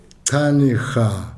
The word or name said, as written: chane-ha